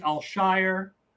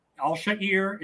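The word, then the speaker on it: Al-Shaair: Aziz Al Shire. – How's it supposed to be? – Al Shair. It does not Al Shire does not sound Arabic to me.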